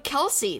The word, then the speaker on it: Kelsi: Use the promo code Kelsey. That's my name. K-E-L-S-I is how it's spelled.